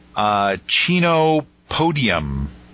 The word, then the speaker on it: Chenopodium: The one genre that I have marked off is Chenopodium. – Right. – Is that how you would pronounce it? – You betcha.